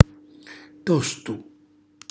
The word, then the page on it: tostu: Calabrese